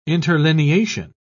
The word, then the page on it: interlineation: British English